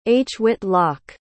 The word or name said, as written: hwitloc